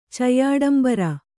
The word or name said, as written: ಚಯ್ಯಾಡಂಬರ